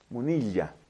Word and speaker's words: Munilla: Hay un obispo relativamente joven en España, de apellido Munilla con doble L, Munilla, habría que pronunciar.